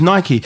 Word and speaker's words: Nike: No, it's Nike. Because then saying it's the American pronunciations, Americans speak English.